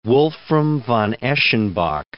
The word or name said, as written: Wolfram von Eschenbach